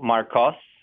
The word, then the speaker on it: Marcos: My name is spelled with an O, Marcos, and uh, the uh, Marcus by Goldman Sachs spelled with a U.